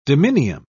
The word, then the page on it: dominium: British English